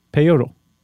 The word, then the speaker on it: peyotl: Okay, but the word they used was peyotl, P-E-Y-O-T-L, peyotl. – Yeah. – Peyotl. – Peyotl.